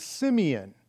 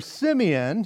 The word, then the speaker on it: Simeon: Now, in the New King James uses the word Simon, S-I-M-O-N. The Old King James, and more of the original Greek, uses the word Simeon, S-I-M-E-O-N or S-Y-M. E-O-N, which would be more of a Jewish spelling and even pronunciation.